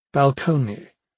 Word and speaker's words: balcony: Balcony, rhyming with pony, and peony, rhyming with pony.